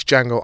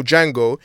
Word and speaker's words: Django: Yeah, because that guy was the original Django. He replies to Django, goes, yeah, I know. That the D is silent because he was the original Django.